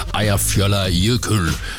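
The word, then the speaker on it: Eyjafjallajökull: Ejer fjoller jøkøl. Mener jeg jo, at den korrekte udtale på et islandske vulkan, der gik i udbrud i her år, skabte en askesky, der nedlagde flytrafik i massevis.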